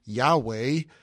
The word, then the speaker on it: Yahweh: I guess the closest we could pronounce it is Yahweh.